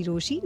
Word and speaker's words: Rochie: मेरा नाम है मिर्ची रोशी,